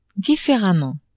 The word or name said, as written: differemment